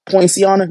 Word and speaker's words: Poinciana: This happened in Poinciana, Florida. I think that's how you say it. Poinciana? Yep.